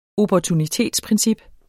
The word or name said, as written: opportunitetsprincip